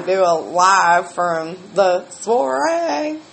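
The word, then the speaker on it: soirée: I call it sorry. He calls it soiree. So she doesn't do those foreign language pronunciation thingies real well. – I'm not French. – Soiree. It's soiree.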